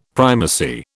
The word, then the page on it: primacy: British English